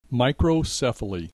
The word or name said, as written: microcephaly